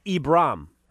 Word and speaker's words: Ibram: You're not familiar with Ibrahim. I'm sorry, Ibram. I don't want to mispronounce his fake name.